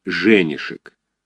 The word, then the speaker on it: Женишек: Много лет тому назад он от кого-то вскользь слышал, что Леночка долгое время была невестой офицера, и что офицер этот со странной фамилией Женишек, с ударением на первом слоге, как-то нелепо и неожиданно застрелился.